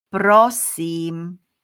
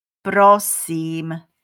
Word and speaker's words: Prosím: Prosím. Repeat after me. Prosím. One more time. Prosím.